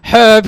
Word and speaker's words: herb: It says, it's pronounced herb because it's got a fucking H in it.